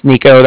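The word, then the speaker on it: Niko: Like I said, my name's Nico. That's N-I-K-O. And the Bank of Cardiff is spelled C-A-R-D-I-F-F, as in Frank Frank.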